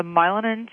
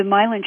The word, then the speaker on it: myelin: Well, we were just talking about the myelin sheath.